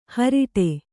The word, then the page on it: ಹಾರಾಡು: Kannada